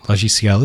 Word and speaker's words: Logiciel: Logiciel is how you say program in French, but this is called Logiciel, and I spelt it wrong on my notes, which made me say it wrong, because I'm awesome.